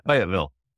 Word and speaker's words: Fayetteville: If you're in North Carolina, you don't say Fayetteville, that's Arkansas. You say Fayetteville.